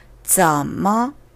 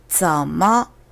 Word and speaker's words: 怎么: 怎么 어떻게 어째서 어떤 방법으로 어쩌머 전의 z는 그냥 지읒의 지가 아닙니다. 센스가 있으신 분들은 저의 발음을 듣고 아셨을 거예요. 짠. 굳이 한국적인 설명을 해보자면, 어, 한심한 모습을 봤을 때, 쯔쯔 하면서 혀를 차실 때 있잖아요. 이 혀를 차는 것과 약간 비슷합니다. 하지만 소리가 나올 때 공기가 동시에 좀 더 나온다는 거. 음, 공기 반, 소리 반, 바로 이럴 때 하시면 Z로 된 발음을 잘한다는 소리도 들으실 수 있어요.